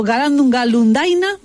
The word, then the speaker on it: Galandum Galundaina: Ayer con Mr. Simon's Hell y los Trogloditas, hoy Galandum Galundiana, o Galandum Galundaina, mejor dicho.